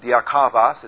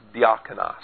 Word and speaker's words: diakonos: It's not a V, it's a nu, which is pronounced like an N. It's not diakavos, it's diakonos.